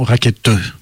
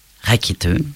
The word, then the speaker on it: Rackete: Alors, je vais finir par faire un petit point de prononciation, Rackete. – Rackete. – Rackete, voilà.